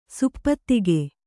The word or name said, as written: ಸಪ್ತಕ